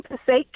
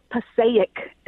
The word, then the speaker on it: Passaic: and I live in Passaic County, and everybody says Passaic, and it drives me insane. – So Passaic, it's, it's just two syllables, is what you're saying. Passaic. That's it.